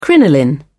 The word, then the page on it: crinoline: British English